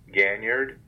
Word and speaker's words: Ganyard: It's Clifton Ganyard, G-A-N-Y-A-R-D.